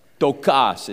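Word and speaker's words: ptochos: It's got a silent P at the front, P-T-O, and it's a long O, C-H-O-S. Tokas is actually the way you'd pronounce that.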